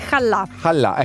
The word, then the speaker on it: challah: Cioè, se tu non sai pronunciare shallah, non è che mi sembra che è challah.